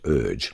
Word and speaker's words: Urge: And he was about to announce my name, which was then Les, U-R-G-E, pronounced Urge in Hungarian, but most people in Australia pronounced it Urge.